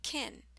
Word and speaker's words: can: Can actually sounds like kin and has a shorter vowel sound. Kin, kin, kin.